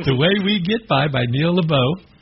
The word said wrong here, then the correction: LaBute: Am I saying that right? – LaBute. – LaBute.